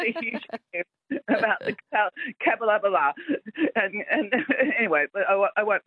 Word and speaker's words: Capalaba: Because that's how, if you look at it, it's C-A-P-A-L-B-A, Cap-L-Laba.